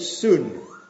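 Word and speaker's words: soon (σύν): When he uses the word together with, okay, together with, or together, it's a little Greek word that we would pronounce in English. We'd pronounce it soon.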